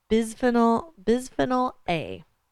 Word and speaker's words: bisphenol A: So BPA is a bisphenol A. – Mm-hmm. – Did I say it right? – Okay. Sounds good to me.